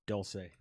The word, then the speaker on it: Dulce: Did I say it right? – No. Dulce.